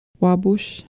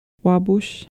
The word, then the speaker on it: Wabush: P can also be pronounced like English B, as in tub. For example? – Rabbit. – Wabush. Wabush.